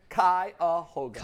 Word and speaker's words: Cuyahoga: Cuyahoga.